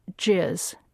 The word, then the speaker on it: jizz: and that is JIZZ, J-I-Z-Z, and it comes from a military acronym. Actually, the acronym itself is G-I-S-S, but it's been converted for pronounceability to J-I-Z-Z, JIZZ.